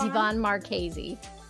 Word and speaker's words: Yvonne Marchese: It's Yvonne Marchese.